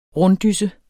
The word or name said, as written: runddysse